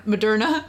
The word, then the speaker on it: Moderna: Moderna? I don't know. – Moderna.